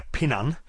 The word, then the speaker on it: Pinan: And the original Okinawan pronunciation for the first ideogram is pin, where the Japanese pronounce it he. So therefore that's where we get the two pronunciations of pinan and hian.